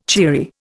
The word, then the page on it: cheery: Indian